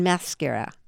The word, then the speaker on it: maschera: Un ballo in maschera. No, that was terrible. – No, that's okay. Say maschera. – Maschera. – Now say maschera. – Maschera. – But you want the accent on the ma. Maschera.